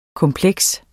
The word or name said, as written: kompleks